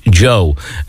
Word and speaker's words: DJO: A little bit of the end of the beginning and spelt D-J-O. Whether that's DJ and an O, or is it just, hey, it's Joe. It's pronounced Joe.